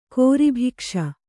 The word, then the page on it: ಗರ್ಭಕೋಶ: Kannada; garbha kōśa